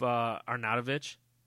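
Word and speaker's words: Arnautović: up Arnautovic. Did I say it right again? – Yeah, you did.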